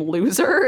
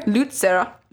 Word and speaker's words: Luzer: but it kind of looks like you pronounce his first name loser. It's L-U-Z-E-R, and his last name is Twersky, maybe Luzer, Luzer. – Lutzer? – Lutzer. – Lutzer. – Twersky.